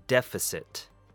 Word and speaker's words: deficit: And our last one would be deficit, three syllables. De has the short E sound, f has the short U sound, and sit sounds like what you would command a dog to do.